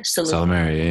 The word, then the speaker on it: Salumeria: How do I pronounce? Is it Salumeria? – Salumeria, yeah.